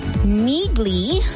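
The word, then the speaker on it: Neagley: Neagly, I finally said it right. Neagly is the Reacher spinoff series, and it will arrive on Prime Video today, September 16th, or tomorrow, officially, September 16th.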